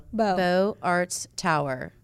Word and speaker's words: Beaux Arts: The Beaux Arts Tower, and I could be 100% saying that wrong. It's B-E-A-U-X. – Beaux. No, it's Beaux Arts Tower.